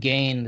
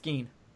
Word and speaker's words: Gein: Is that how you say his name? – Gein? Yeah, Ed Gein. – I've been saying Gein this entire time. – Oh, no, it's Gein.